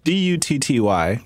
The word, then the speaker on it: Dutty: Petty Belafonte said, "How do you say this first word? D U T T Y.